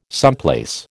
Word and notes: someplace: American English

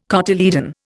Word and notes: cotyledon: Indian

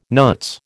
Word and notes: nuts: American English

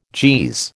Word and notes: Jeez: American English

